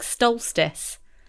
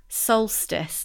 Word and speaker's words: solstice: I keep feeling like there should be an it should be ST, like stolstice. But I know it's not. – Stolstice? – Solstice, that's how you say it, isn't it? Summer yeah.